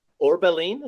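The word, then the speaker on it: Orbelín: Mexico international Orbelin, is that how you pronounce it? – Yeah, you said it good. That was good.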